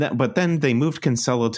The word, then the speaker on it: Cancelo: It was like a weird, like, it was like Kyle Walker played for a half and is it Cancelo? Cancelo, however you say his name? – Cancelo, I think it Cancelo. – Is. – Cancelo, yeah. – Not that we've ever been right about pronunciations on this podcast, but I'm going Cancelo.